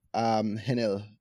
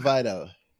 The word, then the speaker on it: Henel Vidal: So the H is silent. Enel Vidal.